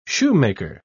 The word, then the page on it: shoemaker: British English